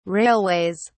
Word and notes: railways: British English

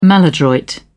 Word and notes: maladroit: British English

